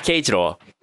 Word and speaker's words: Keijiro: Like, uh, you, you got the names better, but Keichiro Korenaga. Ah, Keijiro.